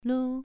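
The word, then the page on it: lu: tone 2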